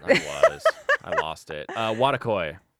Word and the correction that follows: Wotakoi: W A T A C I think it's Wotakoi.